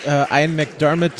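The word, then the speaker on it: McDiarmid: Und ich muss aber auch sagen, für das, was Ian McDermott, so spricht man den übrigens aus,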